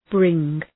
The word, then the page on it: bring: brıŋ